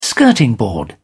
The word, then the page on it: skirting board: British English